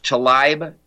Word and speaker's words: Tlaib: I think it's pronounced Talaib, T-L-A-I-B.